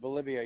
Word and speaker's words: Bolivia: Is that pronounced Bolivia, North Carolina? – That's correct, Bolivia, yep.